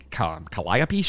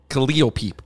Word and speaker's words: Calliope: Wrong. – Calliope. – It's Calliope is how they pronounce it. – Calliope. – I know, but there's so many streets. There's a street that's Burgundy, but they pronounce it Burgundy. It's fucking dumb. It's Calliope. – Calliope. – That's how it's pronounced.